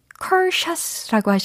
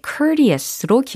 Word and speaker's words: courteous: 어떤 부류는 컬샷이라고 하시는 부류가 있었고, 또 어떤 부류는 courteous라고 하시는 부류가 있습니다. 네, courteous로 기억해 주시면 돼요.